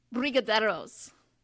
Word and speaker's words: brigadeiros: They're called brigadeiros.